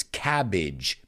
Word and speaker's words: cabbage: It's not cabbage, it's cabbage, bidge, right? It rhymes with the word bridge, cabbage, language, right? A lot of times when we see that A-G-E, it's pronounced as Idge.